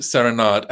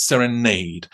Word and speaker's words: Serenade: But before we start, I think the most important thing to talk about is, do we pronounce Serenade correctly? – I was about to say this. Well, as you know, the Balanchine world does pronounce it Serenade, and the Balanchine world is wrong, because the Balanchine himself, from at least the 1950s, was calling it Serenade.